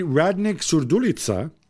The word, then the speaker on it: Radnik Surdulica: They beat Radnik Surdulica uh, nice pronunciation.